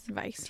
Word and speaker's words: Weiss: So this is TVs, because it's German and I will say it like that. – Okay. – T-Vice. T-Vice. Yeah, probably. – Call it T-Vice. – T-Vice. W-E-I-S-S. – Vice.